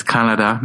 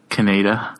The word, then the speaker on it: Kaneda: Well, the correct pronunciation is Akira, not Akira. It's Kanada, not Kaneda.